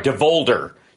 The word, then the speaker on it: Devolder: a.k.a. George Devolver Santos. I think that's how it's pronounced. Devolder, George Devolder Santos, which...